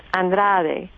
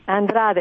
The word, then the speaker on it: Andrade: It's Andrade. – Andrade. – Andrade, and you did a great job.